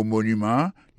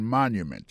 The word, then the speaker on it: monument: Pour mot monument, il tombé sur premier syllabe là, tout. The Washington Monument.